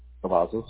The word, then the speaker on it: Cavazos: Cavazos.